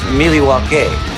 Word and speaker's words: Milwaukee: Actually, it's pronounced Miliwake, which is Algonquin for the good land.